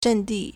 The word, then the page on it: 阵地: zhèndì